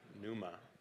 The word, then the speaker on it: pneuma: In Greek, the word for the Holy Spirit that's most often used is the word pneuma. Can you guys say that? Pneuma. Starts with a P. P is silent.